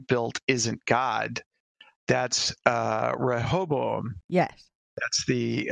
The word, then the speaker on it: Rehoboam: Okay, so here's the trick to pronouncing the word correctly. And the trick is to say hobo and attach two vowels and a consonant on either end. – Okay. – so it's Rehoboam.